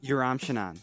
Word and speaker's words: Yoram Shanan: Yoram Shanan.